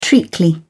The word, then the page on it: treacly: British English